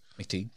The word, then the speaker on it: McTeague: It was directed by James, I don't know how to say his last name, McTeague. – McTeague, yeah.